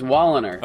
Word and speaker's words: Woliner: I think it's Woliner.